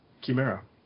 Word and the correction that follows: Chimera: Well, apparently it's actually pronounced Chimera. Uh-oh. – Yeah. – This is according to Stephen Fry, so. – I'm not there. I mean. – Anyway. – I'm going, yeah. C-H-I in Italian is key, so I'm just doing that.